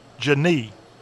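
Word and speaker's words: Jeanne: Her first name pronounced Jeanie. Okay, I'm mispronouncing it myself.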